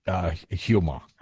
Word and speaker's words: Huma: and do you pronounce it Huma or Uma? – I have always said Huma.